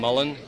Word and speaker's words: Mullen: I'm Tom Mullen. That's M-U-L-L-E-N.